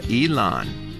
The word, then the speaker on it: Elon: Elon, E-L-O-N, Elon.